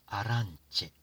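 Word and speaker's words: arance: アランチェ、 C の後ろに E しかないんです。アランチェ、 I が消えてしまいました。これはなぜかというと、イタリア語は CE というスペルでチェと発音します。つまりアランチャ、この複数形であるアランチェ、これがを発音するのに、表すのに I がいらないんですね。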